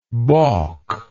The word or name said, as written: baulk